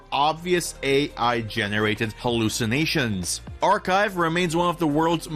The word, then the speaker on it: arXiv: The scientific research repository called Archive, that's spelled A-R-X-I-V, has announced new rules targeting heavily AI-generated academic submissions.